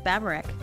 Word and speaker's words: Baburek: Yes, Babarak.